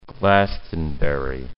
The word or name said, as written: Glastonbury